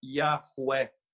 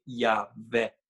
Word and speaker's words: Yahweh: Porque o nome de Deus, a pronúncia mais provável seja Yahweh, ou como outros dizem, Yahvé. Mas Yahweh deve ser o mais provável.